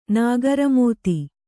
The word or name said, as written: ನಾಗರಮೋಥೆ